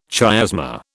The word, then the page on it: chiasma: British English